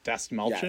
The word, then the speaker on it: Dastmalchian: And the other guy, David Dalmauchin, or how do you say his name? – Dastmalchian? Yes. – Dastmalchian? – Malchian. Interesting, sorry.